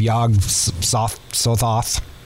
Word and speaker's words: Yog-Sothoth: So then the final scene, there's this birthing of Yog-Sothoth.